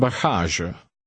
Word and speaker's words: bagage: The first G is pronounced the Dutch way, and the second G is pronounced the French way. Try five. – Waar is mijn bagage?